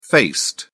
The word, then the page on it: faced: British English